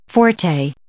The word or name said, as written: forte